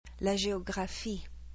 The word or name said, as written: La géographie